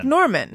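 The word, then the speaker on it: Normand: It's pronounced Norman. Like Norman. – Yeah. – But it's just got a D tacked on the end.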